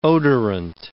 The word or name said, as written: odorant